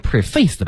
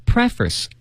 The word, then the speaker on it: preface: 这词儿要读成 preface 吧？ – 为什么？ – 因为它读错了呀。 – 啊、 oh, ，preface。 – 对，朋友们，这个词啊，看着好像挺简单的，pre 加上一个脸部的这个 face 是吧？ – 嗯。 – 但它发音不一样，它叫读成 preface。